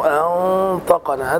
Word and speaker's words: أنطقنا: في كلمة أنطقنا عندك الهمزة مرققة، فالخطأ أن تقول قالوا الهمزة مرققة، أنطقنا، هنا إخفاء حقيقي لوقوع الطاء بعد النون الساكنة، وهي من حروف الإخفاء، من حروف صف ذا ثنى، فالقاعدة هنا هي الإخفاء.